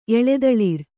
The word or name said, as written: ಎಳೆದಳಿರ್